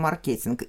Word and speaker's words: маркетинг: Мы чаще uh-huh. всего произносим маркетинг. И уже словарь это разрешает, как две возможных формы.